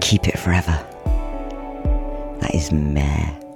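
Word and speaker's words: Maere: M-A-E-R-E. I think it's pronounced Mare.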